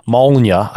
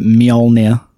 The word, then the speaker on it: Mjolnir: Sorry, the J is pronounced as a Y, so you pronounce it Mjolnir.